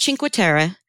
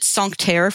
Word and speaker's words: Cinque Terre: I think it's Cinque Terre. I called it Sancterre forever because I assumed it was okay. French.